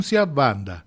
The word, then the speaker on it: Wanda: Todo en Wanda se pronuncia Banda, gira alrededor de estas piedras.